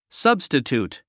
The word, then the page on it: substitute: sʌ́bstət j ùːt; サブステチュート